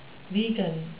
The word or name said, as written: vegan